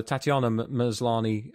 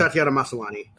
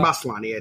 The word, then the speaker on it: Tatiana Maslany: So for Tatiana Maslany, I keep thinking I'm mispronouncing her name, and I probably am. But no, I think you got it right. Okay. Tatiana Maslany. – Okay. – Or Maslany, I think, maybe.